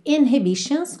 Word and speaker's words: inhibitions: Nesse próximo trecho, a gente tem uma palavra muito interessante, que é a palavra inibições em inglês, que tem duas pronúncias possíveis. Ela é uma palavra que tem uma letra H, que pode ser pronunciada ou não. Então, a gente tanto tem inhibitions com H, tem esse h, ok? Inhibitions, quanto inhibitions. Aqui na música, a gente não ouve o H, então a gente vai ter no inhibitions, make no conditions.